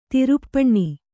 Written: ದರ್ಪಣ

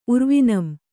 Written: ಉರ್ವಿನಂ

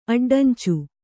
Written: ಅಡ್ಡಂಚು

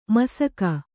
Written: ಮುಸುಕ

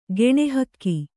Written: ಗುಣಿಹೊಗು

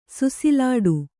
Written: ಸುಸಿಲಾಡು

